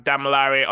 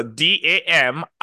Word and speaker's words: Damilare: Instagram, Damilare underscore Adieri, which is my name.